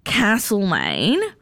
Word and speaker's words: Castlemaine: Triana has messaged us through, and it says, regarding Victoria's Castlemaine, I promise you it rhymes with hassle pain, not parcel pain, and the locals will tell you if you say it wrong. So it is Castlemaine.